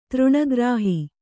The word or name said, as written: ತೃಣಗ್ರಾಹಿ